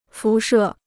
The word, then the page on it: 辐射: fú shè